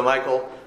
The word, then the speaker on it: Michal: And Michal, David's wife, told him, saying, "If thou save not thy life tonight, tomorrow thou shalt be slain." So Michal, or Michal, depending on how you want to pronounce it, let David down through a window, and he went and fled and escaped.